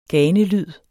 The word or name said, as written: ganelyd